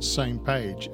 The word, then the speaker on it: SMPAG: It's pronounced Same Page, S-M-P-A-G, Same Page.